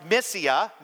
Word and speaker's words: Mysia: And when they had come up to, that's pronounced Mysia, they attempted to go into Bithynia, but the Spirit of Jesus did not allow them.